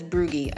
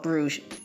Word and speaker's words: Bruges: Bruges is spelled B-R-U-G-G-E. I have one of my best friends that says Brugge. I said, it's not Brugge. It's called Bruges.